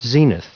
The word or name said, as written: zenith